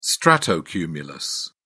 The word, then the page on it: stratocumulus: British English